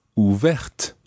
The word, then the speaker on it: ouvert: So, ouvert ends in a T, which is silent. Ouverte ends in the TE, making the T pronounced.